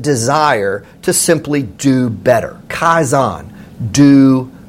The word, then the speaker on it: Kaizen: She also introduced this really interesting word that I've never forgotten, and I believe it's pronounced Kaizen, K-A-I-Z-E-N, and it's a Japanese term that means continuous improvement.